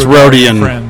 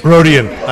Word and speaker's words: Rodian: Why would you be more accepting of Greedo, of a damn filthy Rodarian, than of someone? – Hey, I'm sorry. – It's Rodian. – Rodian. I'm sorry. Who's it? Rodarian. I added a syllable.